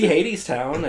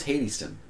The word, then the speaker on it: Hadestown: I'm going to see Hadestown, I think, next month. – I thought it was Hadeston. – Oh my god, I've been saying it wrong the whole time.